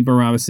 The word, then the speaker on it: Barabbas: And that would be Barabbas and not the American pronunciation.